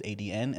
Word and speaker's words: ADN: So I was like, okay, so I started introducing myself as, hey, my name is ADN, like the letters ADN.